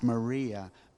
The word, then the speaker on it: Maria: In the New Testament, in the Greek New Testament, it's pronounced Maria, but in our language, Mary.